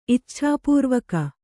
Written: ಇಚ್ಛಾಪೂರ್ವಕ

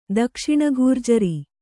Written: ದಕ್ಷಿಣಘೂರ್ಜರಿ

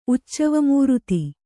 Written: ಉಚ್ಚವಮೂರುತಿ

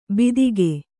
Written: ಬಿತ್ತಿಗೆ

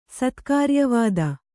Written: ಸತ್ಕಾರ್ಯವಾದ

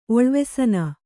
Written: ಒಳ್ವೆಸನ

